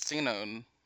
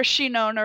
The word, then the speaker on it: Sinon: is Sinon. I hope I'm saying that correctly. – Yeah, I think so. Or Shinon, or if you want to be sound Japanese.